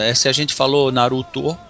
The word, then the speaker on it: Naruto: você fala Naruto, mas é Naruto. Naruto. Né? É Naruto, é, exatamente. É, o O vira U, né? Assim como o E vira I, né?